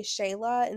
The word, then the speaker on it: Shealah: I decided to call it Sincerely Shayla